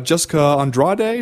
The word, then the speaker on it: Jessica Andrade: Or you could do it with this weekend, for instance, where you got Liz Carmouche and Jessica Andrade. Is that how you pronounce her name? – Sure, man. Okay. You nailed it.